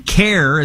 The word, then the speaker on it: CAIR: CARE is how people pronounce it, C-A-I-R.